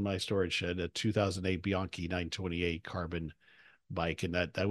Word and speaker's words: Bianchi: Is it Bianchi, or how are you supposed to pronounce it? – Yep, you're right. It's Bianchi.